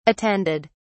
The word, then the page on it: attended: British English